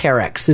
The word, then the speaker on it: Arc'teryx: It's Arc'teryx is how it's pronounced.